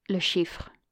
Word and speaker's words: Le Chiffre: Le chiffre.